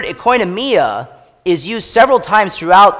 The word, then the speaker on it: oikonomia: That word dispensation in the Greek is called oikonomia. And you can really hear our word economy in when I pronounce the Greek word oikonomia.